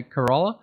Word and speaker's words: Kerala: K-E-R-A. – Yeah, Kerala.